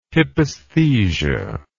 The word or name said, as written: hypesthesia